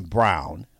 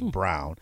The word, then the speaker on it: Braun: Christian Brown, they spell it Braun, but I think it's Brown.